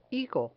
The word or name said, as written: higo